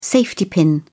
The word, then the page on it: safety pin: British English